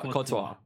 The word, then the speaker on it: Courtois: Courtois.